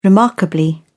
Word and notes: remarkably: British English

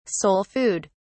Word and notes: soul food: British English

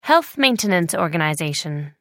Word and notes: health maintenance organization: British English

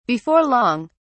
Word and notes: before long: British English

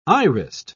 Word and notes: irised: British English

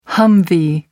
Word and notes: humvee: American English

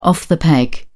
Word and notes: off the peg: British English

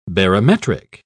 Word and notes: barometric: British English

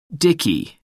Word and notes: dicky: American English

